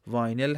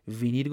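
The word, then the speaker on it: vinyl: تلفظ دقیقش واینل هست که در فارسی به طور رایج وینیل گفته میشه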